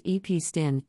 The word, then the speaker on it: Epstein: Founded in 1921, Epstein, pronounced EP Stin,